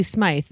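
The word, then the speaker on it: Smyth: Oh, they're calling her Patty Smith. No, she's Patty Smythe. That's how you spell it.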